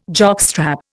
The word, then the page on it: jockstrap: Indian